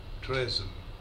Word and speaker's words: treason: Say treason with weed vowel. Some of the students pronounced TREASON after TREASURE. So after treasure, they pronounced treason. But that's not right. Say treason.